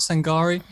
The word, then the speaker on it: Sangare: Could you just tell us a little bit about Sangare? Am I even pronouncing that his name correctly? Sorry, my French is terrible. – Yeah. You are.